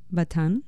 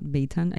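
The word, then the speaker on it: Bataan: Joe Batan. That's how I would say it, not Batan. I think Batan is incorrect.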